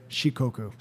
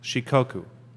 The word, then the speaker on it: Shikoku: Shikoku pilgrimage. – I'm going to avoid that word, Shikoku. – Okay. – How do you say it? – Shikoku. – Shikoku. – She is four, and Koku is region.